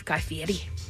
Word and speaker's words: Guy Fieri: Rapper Logic, Chef Guy Fieri. You have to say it like that.